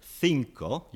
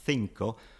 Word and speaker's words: cinco: In Spanish from Spain, you say cinco. You have got a lisp for number five, cinco. Mm-hmm. Whereas in Spanish from South America, they say cinco.